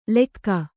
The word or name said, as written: ಲೆಕ್ಕ